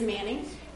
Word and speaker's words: Manning: For those of you who weren't here on that Sunday and don't know who I am, my name is Sarah Manning, and it's spelled funny, just like Pastor Larry's is spelled funny, but it is Manning, and my title is Assistant to the Bishop for Congregational Life.